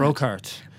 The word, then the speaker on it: Brockhart: How do you pronounce that? – Brockhart. – Brockhart, which sounds sort of, it sounds sort of Flemish or Dutch or something.